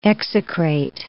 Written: execrate

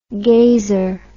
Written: gazer

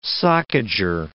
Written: socager

